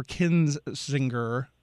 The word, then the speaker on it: Kinzinger: That is a letter from the family of Republican Representative Adam Kissinger. Or Kinzinger is how that is pronounced, I believe.